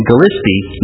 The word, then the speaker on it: Gillespie: Staunton is the town I came here from in Illinois, and Gillespie, notice, I pronounced it Gillespie. It rhymes with crispy, and most people say Gillespie, and that's how you know if they are from Gillespie or not, whether they say it correctly.